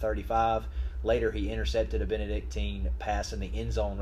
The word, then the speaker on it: Benedictine: 42-35 over Benedictine. I think I'm saying that right. I like saying that. – Benedictine.